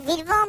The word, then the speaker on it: Bilbao: Ne bileyim ben Vilvao. – Mesela yani atletik Vilvao mu dememiz gerekiyor? – öyle okunuyormuş orada.